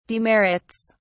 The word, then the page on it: demerits: British English